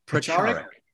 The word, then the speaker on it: Pracharak: In 2009, the West Michigan Hindu Temple ordained him as, I hope I'm pronouncing this correctly, Pracharak? – Pracharak. – Pracharak. Ah, it was my second guess.